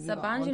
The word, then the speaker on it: Sabancı: Kako se izgovara Sabancı? – Sabancı Univerzitet je sve okej.